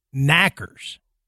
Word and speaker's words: knackers: Well, for the Cornish would I guess it's the pronunciation would say it would almost be like knackers.